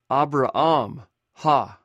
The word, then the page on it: abraam: Greek